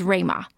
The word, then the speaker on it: Ruima: You can find us on Twitter at ThePanDaily, and my personal Twitter account is Rayma. That's spelled R-U-I-M-A. – My Twitter is G-I-N-Y, G-I-N-Y, kind of an anagram of my first name.